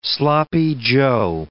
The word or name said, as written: sloppy joe